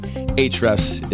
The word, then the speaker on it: Ahrefs: That's how it's spelled, but Ahrefs is how it's pronounced.